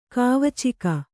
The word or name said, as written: ಕಾವಚಿಕ